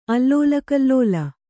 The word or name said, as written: ಅಲ್ಲೋಲಕಲ್ಲೋಲ